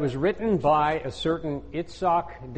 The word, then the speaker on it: Yitzhak: In Yitzhak shall your seed be called. In Isaac shall your seed be called. It's pronounced Yitzhak.